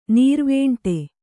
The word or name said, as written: ನೀರ್ವೇಂಟೆ